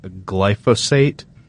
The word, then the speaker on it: glyphosate: But Roundup Ready soybeans, which first showed up in 1996, they're resistant to the active ingredient in Roundup, which is called glyphosate. I think I'm saying that right. – Yep.